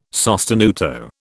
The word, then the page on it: sostenuto: British English